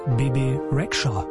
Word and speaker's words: Bebe Rexha: Bebe Rexha, oder wie sie original auf albanisch wohl ausgesprochen wird, Bebe Rexha.